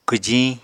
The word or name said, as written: kwjiñ